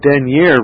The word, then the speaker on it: dernier: and as head of the Church is the denier resort, that's spelled D-E-R-N-I-E-R, resort, R-E-S-O-R-T, in all ecclesiastical causes, an appeal lying ultimately to him in chancery from the sentence of every ecclesiastical judge.